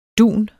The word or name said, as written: dunet